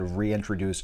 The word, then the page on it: reintroduce: American English